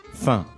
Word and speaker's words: faim: Faim. – Faim. It's like a nasally vowel, right? – It is.